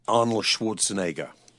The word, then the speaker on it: Arnold Schwarzenegger: Arnold Schwarzenegger.